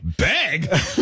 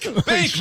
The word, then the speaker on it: bake: Like, I love that she was like, beg? And he's like, no, bake, bake. But it still sounds like beg.